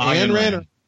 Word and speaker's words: Ayn Rand: to objectivism, which, you know, is kind of based on the writings and the philosophy of and I always say her first name wrong. Is it Ayn Rand? Is it Ayn Rand? Yeah, I've been corrected.